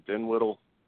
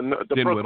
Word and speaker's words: Dinwiddie: How do you say his name? Dinwiddle? The Dinwiddle. – Brooklyn net.